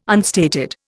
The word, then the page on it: unstated: Indian